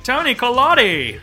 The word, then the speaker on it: Toni Collette: And I have to say, Toni Collette is that how you say her name? Am I fucking up her last name? – That's exactly how I say it every time.